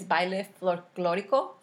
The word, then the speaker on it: baile folclórico: And then at church, we were part of this dance group, which is in Spanish, it's baile folclórico, mm-hmm.